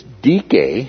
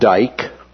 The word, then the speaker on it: Dike: It's dike, it's not dike, okay? It's not decay, right? But dike, that was the daughter of Zeus,